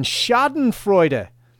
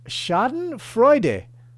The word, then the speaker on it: Schadenfreude: All right, the first one, Schadenfreude. Pronunciation, Schadenfreude, I think, is the pronunciation, which sounds a bit German. And, of course, it's a German word. It's spelled S-C-H-A-D-E-N-F-R-E-U-D-E. That's Schadenfreude.